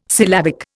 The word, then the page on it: syllabic: Indian